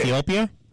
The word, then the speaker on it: Ethiopia: Ethiopia? – Ethiopia, Utopia. I'm saying Utha. Yeah, never mind. We're not euthanizing anybody on the show here. You, especially not those people, but Ethiopia.